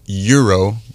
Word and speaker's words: gyro: G-Y-R-O. What is the correct way to pronounce it? – So it's pronounced gyro, but if you say gyro, the person at the cash will understand you. So whatever way you say it, it's acceptable.